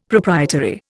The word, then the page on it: proprietary: Indian